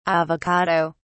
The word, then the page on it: avocado: British English